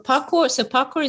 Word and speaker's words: parkour: So parkour is spelled P-A-R-K-O-U-R, and it's a bastardization of a French word of parkour, which sounds the same, which is P-A-R-C-O-U-R-S.